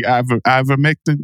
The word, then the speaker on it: ivermectin: I say horse tranquilizers because I don't know how to say ivermectin. Is that how you say it? – Good job.